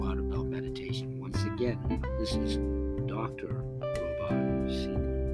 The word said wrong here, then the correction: Robban: And I believe she pronounces it Robin. It's spelled R O B B A N.